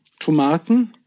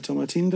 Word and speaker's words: Tomatin: Brennerei ist Tomatin, Tomaten, Tomatin je nachdem, welcher Teil von Schottland man ist, wen man zuhör, äh, zuhört, wird anders ausgesprochen. Ich sage immer Tomatin dazu.